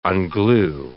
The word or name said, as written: unglue